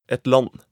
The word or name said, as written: et land